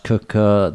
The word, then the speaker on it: Quooker: So this sponsor is, this podcast is sponsored by Cooker, spelt Quooker, pronounced Cooker.